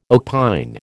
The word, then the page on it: opine: American English